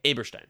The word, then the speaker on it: Eberstein: I would say the uh, the e at the beginning of the last name Okay. will be pronounced a. A. Okay. Aberstein. Aberstein. There you go.